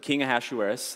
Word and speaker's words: Ahasuerus: King Ahasuerus, which took me a while to learn to pronounce, but King Ahasuerus, the king of Persia, okay, reigned from India to Ethiopia, 127 provinces.